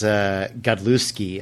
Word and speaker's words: Godlewski: So a cool cover, and I didn't realize this, that Goalie Winsick, if I'm saying that right, probably not, is doing the art, but L. Tabe and John Timms are doing the covers for all the rest of the book. So I didn't even remember, was Timms' last book drawing the interiors, was that 15? – It might have been, because Godlewski, I think is how you pronounce it.